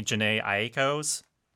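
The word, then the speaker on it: Jhené Aiko: I always pronounce her name wrong, so I'm going to say it once and then Jason's going to correct me. Janae Aiko's Chalumba. – Yeah, you got it.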